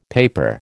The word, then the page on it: paper: American English